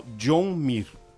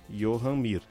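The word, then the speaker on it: Joan Mir: Eu vi o piloto se apresentando, é, inclusive num vídeo da Suzuki, como John Mir, né? Mas eu tô acompanhando como o pessoal na transmissão o chama e como também a outra emissora que transmitia até o ano passado também chamava o piloto, né? Johan Mir.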